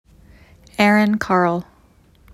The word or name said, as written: Carll Erin